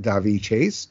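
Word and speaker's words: Daveigh Chase: We have Lilo is voiced by, I think her name is pronounced Davi Chase.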